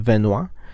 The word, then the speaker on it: Benoit: Bueno, este tema en particular es sobre el ex luchador profesional y salón de la fama del deporte en Canadá, Chris Benoit. Pronuncio así su apellido porque es francés.